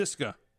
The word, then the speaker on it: Szyszka: Here's my birth certificate. SZYSZKA, and it's pronounced Szyszka. – Yes. We Americanized it. If you're Polish, you can call me Szyszka.